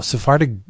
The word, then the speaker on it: Sephardic: I have no idea that you know, Sephardic Judaism.